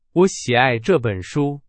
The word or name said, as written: 喜爱